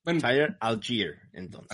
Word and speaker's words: Allgeier: ¿Es Allgeier o Allgeier? – Allgeier, ok.